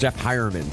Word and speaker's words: Heuerman: And I will apologize, I mispronounced Jeff Heuerman. So H-E-U-E is high somehow.